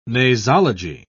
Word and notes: nasology: British English

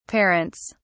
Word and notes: parents: British English

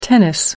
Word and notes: tennis: British English

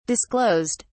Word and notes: disclosed: British English

